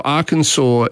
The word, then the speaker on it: Arkansas: So Arkansas is pronounced the way the French would have pronounced it, with the consonant being left off at the end.